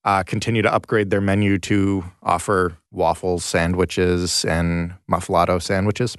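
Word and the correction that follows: muffuletta: Muffaletto, did I say that right? – Muffaletta. Italian muffaletta is actually what it says.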